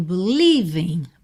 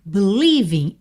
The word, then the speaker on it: believing: Então, a gente acabou de ver na estrofe anterior que a palavra believe, ela tem a sílaba tônica no li, certo? Então, aqui a gente tem believing, believing.